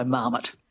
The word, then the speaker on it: marmot: So it's had a lot of time, you know, to sort of be squished into an English model of stress, which would be the stress on the first syllable, so marmot.